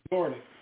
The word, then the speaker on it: resorted: Yes, actually, that was a funny one, resorted. – Resorted. – Yes, a Z on that one. It's an S, but it's pronounced with a Z.